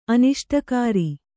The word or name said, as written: ಅನಿಷ್ಟಕಾರಿ